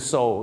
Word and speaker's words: sew: ตัว EW ถ้าเป็น SEW ก็คือ so